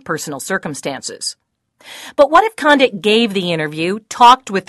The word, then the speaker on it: Condit: Condi in French, C-O-N-D-I-T, means something sugary sweet used to mask a bad-tasting medicine. In English, it's pronounced condit, as in Gary,